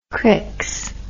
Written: crix

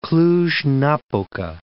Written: Cluj-Napoca

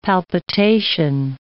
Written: palpitation

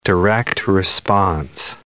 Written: direct-response

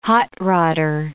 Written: hot-rodder

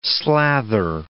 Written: slather

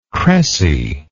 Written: crecy

